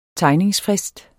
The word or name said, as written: tegningsfrist